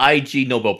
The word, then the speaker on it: Ig Nobel: It's, well, let's just go with Ig Nobel Prize. Okay. – No, no, it's actually Ig. – You actually say Ig? Okay. – It's Ig. – Ig Nobel Prize? – It's Ig Nobel.